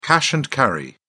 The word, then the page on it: cash and carry: British English